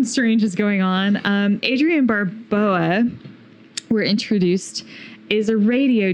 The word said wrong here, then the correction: Barbeau: How do you say it? Barbeau? – Barbeau. – Barbeau. – Boom, boom, Barbeau. – Boom, boom, Barbeau. Beau.